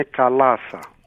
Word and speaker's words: Καλάσα: Καταρχάς το τονίζω σωστά, είναι Καλάς ή Καλάς? – Είναι Καλάσα. – Είναι Καλάσα. – Με το λάμδα το μακεδονίτικο. – Το βάρη. Το γεμάτο.